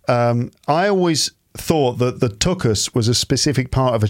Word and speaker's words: tuchus: Just to pronounce it is harder. Tuchus. It sounds more German and, you know, Yiddish.